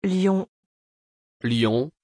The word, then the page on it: Lyon: French